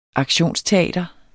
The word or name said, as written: aktionsteater